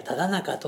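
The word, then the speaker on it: 直中: 直角の直に中と書いてただ中といたしました。まずあの前日だったでしょうか、通りがかった方が、これは何と読むのですかと質問されて、いやただ中と読むのですと。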